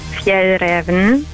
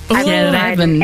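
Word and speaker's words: Fjällräven: Ich bin von der schwedischen Outdoor-Marke und es wird richtig ausgesprochen Fjell Raven. – Fjell Raven.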